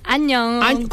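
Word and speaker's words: Annyeong (안녕): Annyeong. Es hola, Annyeong. – Como año, así como estamos en el año 2026.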